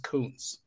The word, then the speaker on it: Kuntz: If you see Zach Kuntz, it's K-U-N-T-Z and it's pronounced Kuntz. Okay. Don't be yelling any other thing around your house. Okay. Kuntz, K-U-N-T-Z. – Like a double O, Kuntz.